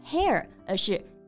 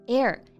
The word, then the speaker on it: heir: 对，我们刚刚说到了 inherit 是继承，那继承者、继承人啊，男性呢我们就用 heir 来表达，女性呢就是 heiress。这两个单词我们都要注意一下发音啊。单词拼写呢都是 h 开头的，但是这里呢 h 不发音，不是 hair，而是 heir and heiress。 – 对，所以说一个继承人前面用 an。an heir, an heiress。但是啊，你看他虽然这么有钱，但是他又这么神秘，是不是因为他的原生家庭，就是说童年不幸福啊？No, she had a happy childhood with doting parents and sister who was her best friend.